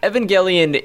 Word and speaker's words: Evangelion: Evangelion.